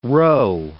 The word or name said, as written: rho